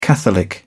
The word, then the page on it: catholic: British English